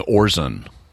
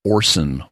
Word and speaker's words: Orson: Instead of saying Orson, he keeps saying Orson. – Right. – It's like, no, that is an S, not a Z sound. It's Orson Welles, not Orson Welles.